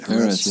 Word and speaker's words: Eretz: Eretz, is that how you say it? – Eretz Israel.